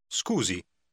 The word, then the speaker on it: Scusi: Scusi.